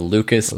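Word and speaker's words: Lukæs: My artist name is Lucas, but spelled with that A that's attached to an E. What are those? – It's like a Gaelic thing, right? – Yeah, so Lucas, but it's like L-U-K-A-E-S.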